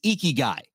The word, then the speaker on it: Ikigai: is Ikigai.